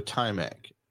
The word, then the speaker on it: Taimak: The cast of this movie is kind of, I don't want to say unique, but I mean, the lead character, Taimak, I think mm-hmm. is how you pronounce his name. Yes. T-A-I-M-A-K.